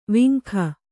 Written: ವೆಂಕ

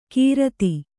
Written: ಕಿರಾತಿ

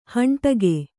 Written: ಹಂಡಕ್ಕಿ

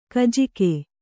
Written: ಖಜಾಕೆ

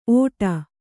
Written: ಓಟ

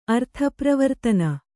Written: ಅರ್ಥಪ್ರವರ್ತನ

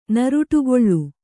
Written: ನರುಟುಗೊಳ್ಳು

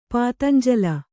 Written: ಪಾತಂಜಲ

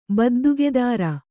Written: ಬದ್ದುಗೆದಾರ

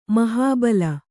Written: ಮಹಾಬಿಲ

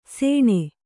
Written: ಸೇಣೆ

